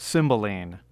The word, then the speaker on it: Cymbeline: And Jan, here is your chance to tell all but maybe the very most ardent admirers of the Bard about Cymbeline. Am I pronouncing that correctly? – You are pronouncing it, yes.